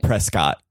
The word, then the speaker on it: Prescott: It's spelled Prescott. – Okay. – Yeah. – But it's pronounced Prescott.